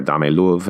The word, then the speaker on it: Dame Love: Actually, this is one of my favorite songs in the past several months, this track Dame Love, or Dame Love, as they pronounce it in the song.